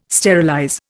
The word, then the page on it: sterilize: Indian